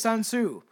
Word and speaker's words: Sun Tzu: Seeing the fact that it's spelled with a T, but I know his name is Sun Tzu.